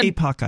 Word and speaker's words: Epoch: Next to the video was a link to describe to the e- I don't know how to pronounce this Epoch Times, Epoch Times, E P O C H Times. It's it's like a epoch, I think. Epoch. – Okay, I knew I was butchering that word.